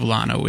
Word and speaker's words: Llano: The unincorporated community of Llano is pronounced with an L, despite its Spanish origin.